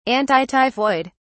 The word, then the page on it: antityphoid: British English